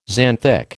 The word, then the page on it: xanthic: American English